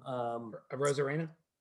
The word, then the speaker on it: Arozarena: Arozarena, yeah.